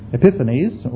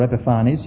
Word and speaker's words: Epiphanes: This was fulfilled in the year 167, and it lasted through to 164, the 3.5 years, when a man called Antiochus, Epiphanes, or Epiphanes, you can pronounce his name several ways,